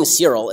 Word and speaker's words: Searyl: Okay, Doty told the CBC, I'm raising Cyril, it's spelled S-E-A-R-Y-L,